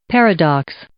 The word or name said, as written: paradox